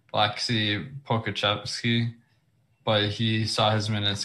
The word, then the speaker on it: Pokuševski: Yeah, and by the way, you did pronounce Pokuševski correctly, at least to my knowledge.